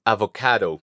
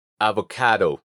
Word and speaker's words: avocado: La primera de ellas es la palabra para aguacate, que en inglés es avocado. Avo. Aunque la palabra se escribe con D al final, normalmente se pronuncia como si fuera una R suave del español. Caro. Avocado.